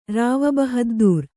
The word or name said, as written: ರಾವಬಹದ್ದೂರ್